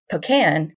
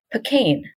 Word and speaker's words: pecan: pecane, stress on the second syllable. It's been switching back and forth ever since. The main thing people disagree on is which syllable to stress.